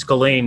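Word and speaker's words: Ghislaine: Yeah, it's Ghislane Maxwell. – Really? – It's a silent S? – Yeah, that's actually how you say it.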